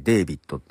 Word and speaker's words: D4VD: えー、続いてはですね、これ D4VD、 D4VD と書いてですね、デイビットって読むらしいんですけども、えー、デイビットのですね、えアルバムが 9 月 8 日に出ておりまして、あ、アルバムには EP だな。